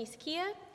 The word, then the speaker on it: Sy-Quia: Um, my name is Stephanie Sy-Quia. It's pronounced like Sakia Starmer, this pronunciation guide is one of the best things about his leadership, as far as I'm concerned.